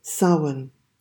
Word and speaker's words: Samhain: Samhain. Now, it's spelt S-A-M-H-A-I-N, but it's pronounced Samhain.